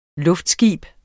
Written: luftskib

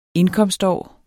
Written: indkomstår

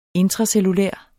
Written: intracellulær

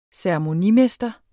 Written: ceremonimester

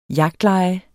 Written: jagtleje

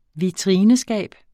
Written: vitrineskab

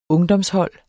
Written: ungdomshold

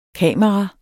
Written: kamera

